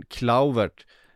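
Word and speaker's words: Kluivert: Och Justin Klauvert. Det är så det ska uttalas, Makoto.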